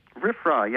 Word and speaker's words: RFRA: RFRA, yes.